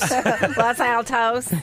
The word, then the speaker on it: Los Altos: Is it Los Altos? – Los Altos.